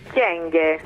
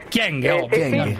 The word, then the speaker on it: Kyenge: Senta, com'è la pronuncia esatta del suo cognome, onorevole Kyenge? – Kyenge. – Kyenge. – Kyenge. – Finalmente.